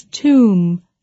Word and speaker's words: tomb: Is tomb. Tomb. – 墓或坟 Tomb is one of those funny words with a B on the end that we don't pronounce. It is not tomb, but tomb.